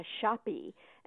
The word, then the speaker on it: shoppe: Now, a retail store that calls itself a shoppy, and here I'm pronouncing the E, so you can tell which spelling I'm referring to, S-H-O-P-P-E.